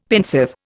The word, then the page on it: pensive: Indian